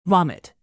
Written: vomit

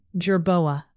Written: jerboa

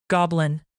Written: goblin

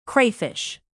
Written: crayfish

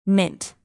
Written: mint

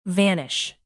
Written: vanish

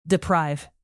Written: deprive

